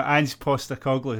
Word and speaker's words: Postecoglou: No, Ange Postecoglou.